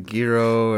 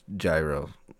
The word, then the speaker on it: gyro: We'd go out, get a gyro, or go out and have, like, you know, Wendy's, and you'd be having to use the bathroom. Yes, it's not pronounced gyro. That's an incorrect pronunciation. It's gyro.